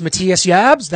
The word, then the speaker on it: Matthias Jabs: I mean, uh, his name is pronounced Matthias Jabs. That's how we will refer to it as.